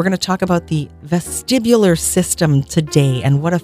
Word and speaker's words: vestibular: The vestibular system.